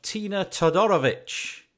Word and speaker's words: Tina Todorovic: Today on the line from Toronto, we have Tina Todorovic. Hi. – Hi. Thank you so much for having me here. And thank you so much for correctly pronouncing my last name.